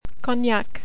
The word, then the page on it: Cognac: French